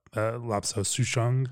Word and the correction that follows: Lapsang Souchong: Did I get that? – Lapsang Souchong, yeah. – Lapsang Souchong, okay.